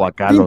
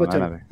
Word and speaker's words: Bachalo: O Ki, Lupaquino, bueno, como sea, porque Chris Bachalo sí es Bachalo, no sé. – Pero o Bacalo. – Tinta es número 2. – En fin, no, perdón, pero Bachalo. – Es de los que sí sé cómo se pronuncia.